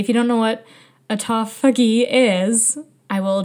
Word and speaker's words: autophagy: I think it's autophagy. I kept saying autography, like photography. I think it's autophagy.